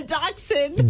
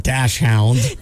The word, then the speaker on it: dachshund: What's the proper... – A dachshund. – Dachshund. – Dachshund. – Not dash hound.